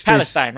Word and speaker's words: Palestine: It's Palestine, right? I'm saying it right? – Yes.